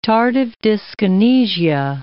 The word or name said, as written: tardive dyskinesia